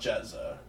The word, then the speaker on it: GZA: And then there's Jezza, which is GZA, but it's pronounced Jezza.